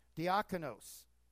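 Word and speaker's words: diakonos (διάκονος): Minister, diakonos in the Greek. That's how it's pronounced.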